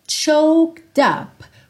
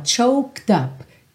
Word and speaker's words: choked: Então, sou and I'm all choked up. Cuidado para você não falar choked up. Esse é não é pronunciado. Então, ó, choked up. Treina esse K com o D.